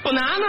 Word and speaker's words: banana: 香蕉，你看，注意发音这。 – 嗯。 – banana。 – 您这坐电门上了是吗？ – 颤音。 – 哦，banana。 – banana。 – 哦，得有一个颤音。